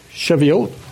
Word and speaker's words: Shavuot: Because, right, 50 days after Passover feast in Jerusalem, the Jews celebrated the festival of Shavuot, I think is the way it's pronounced. Shavuot. That's a good pronunciation, thank you.